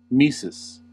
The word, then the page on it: tmesis: American English